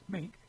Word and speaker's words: Meink: The Secretary of the Air Force, Dr. Troy Mink, M-E-I-N-K, how would you say that? – Mink.